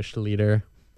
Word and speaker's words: Schlueter: and he spelled it S-C-H-L-E-V-T-O-R, Schlueter. – Schlueter. – So it's been butchered bad before. – That's so funny. – So Schlueter.